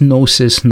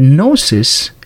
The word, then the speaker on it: Gnosis: For the Gnostics, when someone tried to gain salvation, which they called Gnosis, by the way, this Gnosis, Gnostic with the silent G in the beginning, this is the same word that we have the word knowledge today with K-N-O-W, and this is G-N-O.